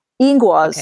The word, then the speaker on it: Ingwaz: Is it Ingvats or Ingwats? – Ingwaz. Okay.